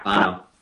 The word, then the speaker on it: Bono: Bono? Is that how you say his name? Bono? I don't know. – Yeah, Bono.